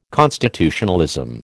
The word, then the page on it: constitutionalism: American English